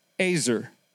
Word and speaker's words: ezer: the Hebrew word ezer, E-Z-E-R is how we would spell it, is the Hebrew word ezer.